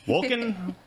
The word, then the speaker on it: Wolken: That's just a coincidence that her last name rhymes with Tolkien. Wolken, working with Tolkien.